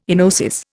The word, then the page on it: enosis: Indian